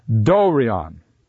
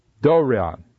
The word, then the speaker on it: Dorion: The one that's in bold is the omega, and it's an O sound. So this is Dorion. Dorion.